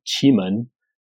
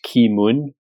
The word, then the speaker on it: Qimen: The base of Anhui Mountain, there's a little city called Qimen, and they do a famous Qimen Hongcha. Which is sometimes translated as Ki Mun, K-E-E-M-U-N, those old wacky Wade Giles way of pronouncing stuff.